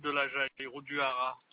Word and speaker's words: Haras: Hara, ça s'écrit H-A-R-A-S.